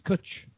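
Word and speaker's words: cwtch: See, we've got a word in Wales, which is cutch. And it's a Welsh word, and it's C-W. And it's spelled differently. C-W-T-C-H. T-C-H, usually, but there's an S in it as well if you speak real Welsh and you're from the north. So they say cutch with an S.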